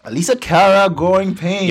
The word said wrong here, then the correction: Alessia Cara: Yo, you're not even ever saying her name right. It's Alessia Cara.